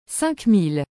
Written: 5000

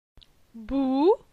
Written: Bou

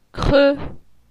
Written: Cre

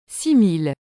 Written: 6000

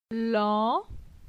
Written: Lan

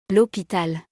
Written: L'hopital

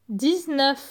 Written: 19

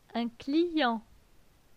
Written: Un client

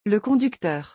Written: le conducteur

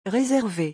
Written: réserver

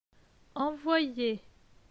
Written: Envoyer

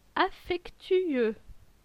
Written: Affectueux

